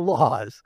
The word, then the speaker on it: Lawz: Although it's spelled ale in English, in the variation of the transliteration, L-A-W-Z, so laws. Isn't that interesting how that, not that it means laws at all, but it just sounds like it means laws.